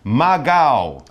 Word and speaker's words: Maqaw: It's got a Q in it, but it sounds more like a G when we say mm -hmm. it mm-hmm. in the correct way. So Maqaw, that's what we're talking about.